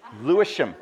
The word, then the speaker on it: Lewisham: Lewisham? Okay, I thought you were sneezing. Okay, Lewisham.